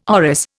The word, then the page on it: orris: Indian